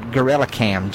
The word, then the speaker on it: GKrellM: Only thing I use it for is I use a plug-in called GorillaCam. GKRELLM Gorilla Cam. I may be spelling it wrong, but that's how it's pronounced.